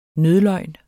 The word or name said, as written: nødløgn